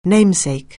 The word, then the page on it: namesake: anglais